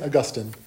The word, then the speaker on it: Augustine: St. Augustine. Very confusing. Every time I try to say that, I get it wrong. But that's the right way to say it.